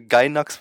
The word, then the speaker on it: Gainax: Also äh, die, die äh, vorher bei Inferno Gainax waren, beziehungsweise Gainax wird es ja äh, offiziell ausgesprochen und dort unter anderem Guren Lagann und äh, ja, viele andere tolle Anime gemacht haben.